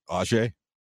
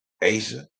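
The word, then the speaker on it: A'ja: Y'all can't pronounce A apostrophe J-A, Asia. And then listen to how they react when they find out it's pronounced Asia.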